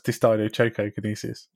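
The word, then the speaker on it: dysdiadochokinesis: What's wrong with it? That's how you say it. Dystidiokinesis. – No, it's not. It's dystidiochokinesis.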